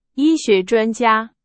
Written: 专家

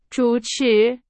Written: 主持

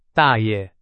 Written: 大爷

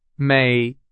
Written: 每

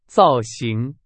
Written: 造型

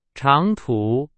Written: 长途

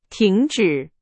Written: 停止

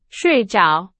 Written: 睡着

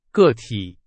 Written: 个体

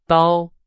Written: 刀

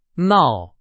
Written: 闹

